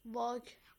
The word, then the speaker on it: walk: Walk. W-A-L-K. – Are you sure? – Yeah. – Okay. So L is silent here as well.